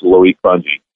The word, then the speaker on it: Loic Fungi: As far as we know, that's how we say his name. It looks like an eye chart, but it's Loic Fungi.